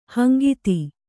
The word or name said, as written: ಹಂಗಿತಿ